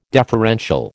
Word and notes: deferential: American English

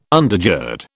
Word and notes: undergird: British English

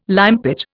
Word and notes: limepit: Indian

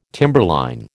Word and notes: timberline: American English

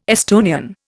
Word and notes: Estonian: Indian

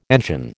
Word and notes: engine: American English

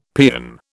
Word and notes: peon: British English

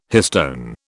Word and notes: histone: British English

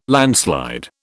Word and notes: landslide: British English

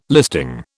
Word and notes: listing: British English

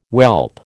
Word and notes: whelp: American English

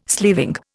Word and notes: sleeving: Indian

